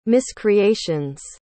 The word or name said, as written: miscreations